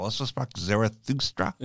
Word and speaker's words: Also sprach Zarathustra: You know, or in also Sprachlar, you know, in that bit, whatever it's called, also Sprach Zarathustra.